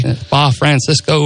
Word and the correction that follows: Francorchamps: or something like that. – Spa Francorchamps. – Francorchamps. – Yeah, close. You were in the ballpark with it.